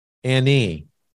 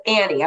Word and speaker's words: Anne: So, but it's Jamie, like the biggest joke with my husband because it's spelled A-N-N-E, but it's Annie. I was named after my great aunt. And so my husband's like, no, that's Anne. And I'm like, no, it's Annie.